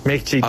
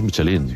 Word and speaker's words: Michelin: Oye, Michelin, ¿cómo se pronuncia definitivamente? – Michelin. A ver, aquí en la península ibérica hacemos Michelin. – Sí, claro. Claro, pero la realidad, tú eres un chef internacional. – Ya, pero... – ¿Tú cómo lo pronuncias? – Me cuesta ser tan tontorraco, ¿no? Dice Michelin. – ¿Michelin? – Yo digo Michelin. – Vale. – Y cuando vas a una gala, pues escuchas Michelin a veces.